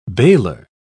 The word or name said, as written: bailor